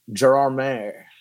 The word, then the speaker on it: Gérardmer: We just had one more, I think, we went to Gérardmer, is how it's pronounced, in the French Alps, which was an amazing festival.